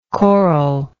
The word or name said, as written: corallike